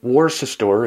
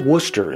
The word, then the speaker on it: Worcester: Worcester would be pronounced Worcester if you pronounced it phonetically, but Worcester is how you say it.